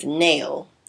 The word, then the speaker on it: knell: So the word for today is nail. It's a noun and it's spelled K-N-E-L-L.